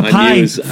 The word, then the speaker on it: python: It's not a python. – I knew as soon as I said that. – I couldn't even laugh. I was so taken aback by you putting the emphasis on the wrong syllable.